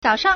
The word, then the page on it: 早上: zǎoshàng